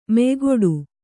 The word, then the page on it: ಮೆಯ್ಗಿಡು: meygiḍu